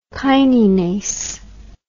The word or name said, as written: kininase